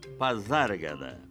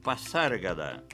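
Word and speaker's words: Pasárgada: Pronuncie Pazárgada. Isso mesmo, com som de Z em zero. Assim pronunciava o próprio Manuel Bandeira, como prova uma gravação deixada por ele. Nem poderia ser diferente, já que o S aí é intervocálico e por isso mesmo tem o som de Z.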